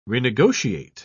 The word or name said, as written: renegotiate